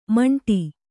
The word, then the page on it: ಮಂಟಿ: maṇṭi